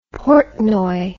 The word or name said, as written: Portnoy